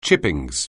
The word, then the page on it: chippings: anglais